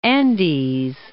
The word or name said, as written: Andes